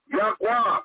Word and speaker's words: Yahweh: English, Lord God, the Medicom, YHWH, pronounce it, Yahweh.